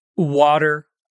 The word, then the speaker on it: water: As palavras party, water, better e totally, embora sejam escritas com T, são pronunciadas com o som de R. Em inglês, geralmente quando temos a letra T entre duas vogais, como em water, ou temos um T depois de um R e antes de uma vogal, como em party, ela pode ser pronunciada com um som de R. O mesmo som do R que vocês têm, por exemplo, na palavra para.